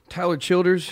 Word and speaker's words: Tyler Childers: And in the meantime, we're going to talk to our friend Tyler Childress. Is that, did I say it right? – Tyler Childers. You can Childers.